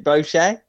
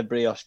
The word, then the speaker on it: brioche: Is it brochet? Is it a brochet bun? – Brioche. – A brioche bun.